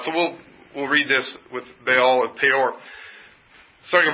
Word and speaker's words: Baal: So the word, we often say it Baal, B-A-A-L, probably something pronounced closer to Baal, or even Baal kind of,